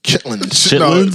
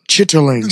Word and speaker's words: chitterlings: How do you say that word? – Chitlins. – There's a TT and an ER in that. – Listen, the correct way on paper, chitterlings.